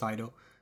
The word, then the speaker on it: Taito: Yeah, I'm pretty sure it's pronounced Taito. Like, so, like very fast, like Taito.